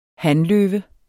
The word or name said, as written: hanløve